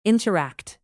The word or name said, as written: interact